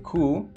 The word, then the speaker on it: coup: Yes, the pronunciation for this word is coup. The spelling is C-O-U-P.